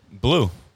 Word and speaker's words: Bleu: Blue. Dallas Blue. But Blue is spelled B-L-E-U. Like blue cheese.